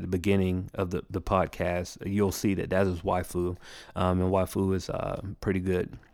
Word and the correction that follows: Wufoo: And I may even be saying the name wrong. It's Wufu, WUFOO.